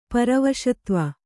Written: ಪರವಾಸುದೇವ

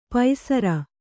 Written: ಪೈಸರ

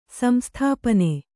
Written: ಸಂಸ್ಥಾಪನ

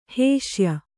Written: ಹೇಸಿಯ

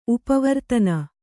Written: ಉಪವರ್ತನ

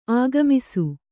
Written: ಆಗಮಿಸು

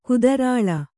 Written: ಕುದ್ರಾಳ

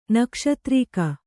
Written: ನಕ್ಷತ್ರಿಕ

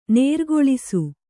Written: ನೇರ್ಗೊಳಿಸು

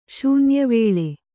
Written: ಶೂನ್ಯವೇಳೆ

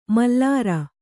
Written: ಮಲ್ಲಾರ

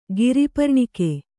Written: ಗಿರಿಪರ್ಣಿಕೆ

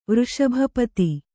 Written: ವೃಷಭಪತಿ

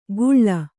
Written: ಗುಳ್ಳ

